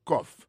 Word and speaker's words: cough: I have a cough. Cough là, c'est C-O-U-G-H, ça veut dire tous. Ma profite d'utiliser quelques autres mots qui finissent par O-U-G-H, puis il faut la donner prononcer avec son off.